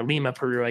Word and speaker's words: Lima: or Lima, Peru, I guess, if you're pronouncing it properly.